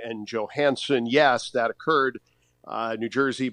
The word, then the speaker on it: Johansson: he is now back in Washington as Marcus Johansson and the hard J has been left on the airplane.